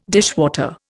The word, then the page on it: dishwater: Indian